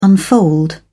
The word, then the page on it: unfold: British English